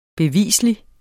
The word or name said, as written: BEVISLIGT